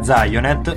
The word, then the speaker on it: Zionet: Oppure abbiamo ancora i nostri contatti Facebook, il gruppo Zionet, scritto Zion con la chiocciola. – Si pronuncia Zionet.